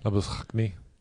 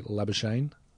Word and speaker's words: Labuschagne: Labuschagne. – I'm going to go with his pronunciation and say Labuschagne. – Is that how he says it? – Well, he dumbs it down for us.